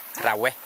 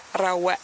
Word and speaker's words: Rawe: In Bunong language, if we say the elephant, we say Rawe. – Rawe? – Yes. – Slon v bunongštině je Rawe.